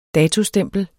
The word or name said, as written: DATOSTEMPLET